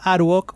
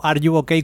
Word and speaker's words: Are You Okay: La comunidad Aruok, o Are You Okay, como se debería de pronunciar realmente, que ni yo lo hago, es una comunidad de jugones, que eran un grupo de personas que nacieron en el foro de Edge, en Zombicide.